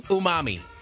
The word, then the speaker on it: Umami: Unami, the House of Unami. – House of Umami. – House of, how you say it? Umami? – Umami. – Umami. – Yeah, there you go.